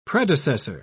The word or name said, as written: predecessor